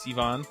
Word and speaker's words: Sivan: Uh, uh, yeah, Sivan, close enough. Sivan.